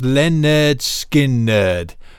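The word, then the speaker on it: Lynyrd Skynyrd: Freebird, the full 9 minutes 18 seconds from their album pronounced Leonard Skinnerd.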